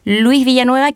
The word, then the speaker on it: Luis Villanueva: Y por otro lado, eh, al oncólogo médico de la Fundación Arturo López Pérez, Luis Villanueva, quien además es presidente de la Sociedad Chilena de Oncología Médica.